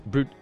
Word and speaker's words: Brute: But uh but yes, that those famous words et tu brute what is it? Brute. – Et tu brute. – Brute because it's spelled B R U T U S.